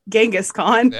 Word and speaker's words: Genghis Khan: Not like Kangas Khan. – I was really ready to make fun of you. I'm so sorry. – And I think actually it's Genghis Khan.